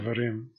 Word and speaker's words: Devarim: Devarim.